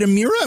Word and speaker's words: Amira: Is it Amira? Am I doing that right? – You said it right. Yeah, right. It rhymes with a miracle.